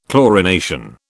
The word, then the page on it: chlorination: British English